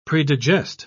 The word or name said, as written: predigest